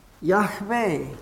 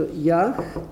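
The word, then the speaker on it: Jahwe: Also das heißt dann Yahwe. Also Yahwe. Yahwe. J, gehauchtes H. Yah, W und H. J-H-W-H. J-H-W-H. Yahweh.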